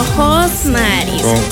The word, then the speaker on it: Josmaris: No, Josmaris. Josmari con J. Ah, okay. Josmaris Fajardo.